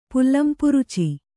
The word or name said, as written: ಪುಲ್ಲಂಪುರುಚಿ